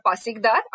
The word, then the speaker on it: pasikdar: So there's a scene in the book which actually talks about the pasikdar. I hope I'm pronouncing it right. – That's right.